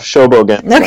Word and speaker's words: Shobogenzo: Dogen's Shobogenzo, if I've pronounced that correctly. – Close enough. Shobogenzo.